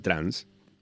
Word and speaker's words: trance: Porque, y este es el segundo tipo de comentario que más aparece, una base más del palo del trans, que se escribe trance, pero se dice trans,